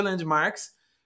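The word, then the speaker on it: Landmvrks: coisa para dizer que essa banda chama Landmarks, se pronuncia Landmarks, só que o Marx, né, em vez de ser com um A, é com um V. Então seria como se fosse um A ao contrário, né?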